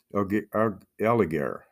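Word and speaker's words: Allègre: Bring up, for example, geophysicist Dr. Claude Allégret, I believe his name would be pronounced A-L-L-E-G-R-E. Allégret, and is from France.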